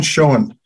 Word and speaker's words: Schoen: And I'd like to welcome to the show the author of this book, Glenn Schoen. Glenn, welcome to the show. And did I say your last name right? – It's Schoen, but that's okay.